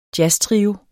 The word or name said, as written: jazztrio